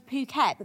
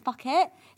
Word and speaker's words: Phuket: I said, oh, I'm going to Phuket, but because it's spelled PH, I read it as fuck it.